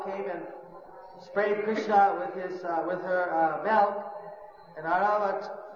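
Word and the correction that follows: Airavata: Aravat, the elephant of Indra, what's the correct pronunciation? Oiravat. Oiravat was spraying water on Indra from the Jamuna.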